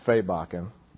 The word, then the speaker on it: Svebekken: And I think one case study that I have right here on the front row, I'm going to embarrass Steve Jobs Svebeken. If you need to, I know how to say it perfectly. S-V-E-B-E-K-K-E-N.